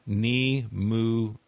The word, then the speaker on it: Nimue: And she points out it's a Welsh name and pronounced Nimue.